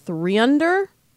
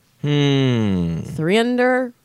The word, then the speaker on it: 3UNDR: Tinder is suing an app called Threeunder. I think that's how you say it, or Threeunder. Threeunder. It's spelled 3UNDR.